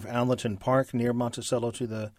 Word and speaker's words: Monticello: I know that when I lived out east and there were other Monticellos written anywhere, most people called it Monticello because that's the correct pronunciation using Italian.